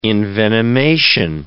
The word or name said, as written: envenomation